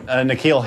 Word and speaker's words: N'Keal: Hakeel Harry? – Oh, N'Keal Harry. – N'Keal? N'Keal. – Yeah. – N'Keal in this case is spelled N'Keal. N'Keal.